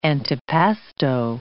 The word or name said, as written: antipasto